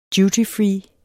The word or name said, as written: dutyfree